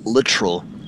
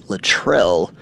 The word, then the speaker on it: Littrell: Hey, man, it's pronounced literal. So the accent is on the front. It's a little more English versus French, because everybody goes Littrell. That's easier to pronounce, but we pronounce it literal.